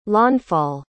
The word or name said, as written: launfal